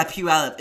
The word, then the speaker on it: Puyallup: If you look at how it's spelled, Puyallup it's kind of like Cuyahoga in Cleveland. Oh, it's oh, yeah. it's spelled P U Y A L L U P.